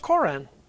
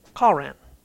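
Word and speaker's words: Koran (קרן): Well, it's pronounced Koran. Not the Koran. Koran. K-A-W-R-A-N. Okay? It's not like the Islamic Koran.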